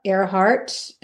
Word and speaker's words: Airhart: I have a website with just my name, Lisa Earhart.